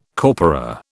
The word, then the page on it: corpora: British English